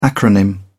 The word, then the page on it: acronym: British English